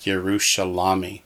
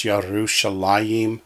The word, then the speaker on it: Yerushalayim: Because it looks like Yerushalami. But the word Jerusalem is pronounced Yerushalayim, even though it looks like Yerushalami. It's an old spelling.